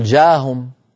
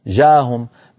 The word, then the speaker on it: جاءهم: يعني مثلا يا بني لا تقل جاهم جاءهم، بين الألف والجيم هناك همزة، جاءهم أهم، هذه الهمزة لابد من نطقها.